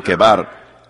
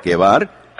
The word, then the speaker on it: Quebar: junto al río Quebar. ¿Lo pronuncié eso correctamente? Quebar. Q-U-E-B-A-R, Quebar.